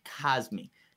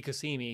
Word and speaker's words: Cosmi: And yeah, I think you're actually right on the pronunciation of the last name, Cosmi. Maybe it's the Mortal Kombat that I was watching. – You do not want it to be Kasimi because it sounds too much like Karimi.